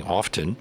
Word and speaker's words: often: You've talked about the word often, which has a T in the middle. It's usually silence. But I do hear some people pronouncing the T saying often.